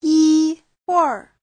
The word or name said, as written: yi1huir4